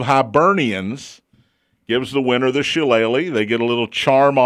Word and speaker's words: Shillelagh: It's the battle, and let me say this correctly. – Say it right. – I have to do it slowly. For the Irish Shillelagh. – Shillelagh is correct.